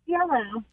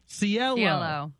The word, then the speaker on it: CeeLo: You almost got it right. It's CeeLo. – CeeLo. – Even producer Nicole wrote it phonetically. C, like the water, L-E-L-L, and then O, CeeLo.